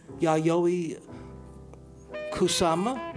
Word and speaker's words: Yayoi Kusama: Yayoi Kusama.